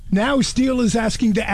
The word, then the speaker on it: STIHL: It is steel, S-T-I-H-L, pronounced steel, the chainsaw company.